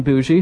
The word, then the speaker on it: Bougie: I was hooked up with you through Mr. Robin Bougie, who I thank very much for the hookup here. – That's how you pronounce it. I wasn't sure if it was Bougie or Boogie.